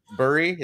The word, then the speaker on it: Buri: This being was Buri. Is that how you say it? – Mm-hmm. Yep.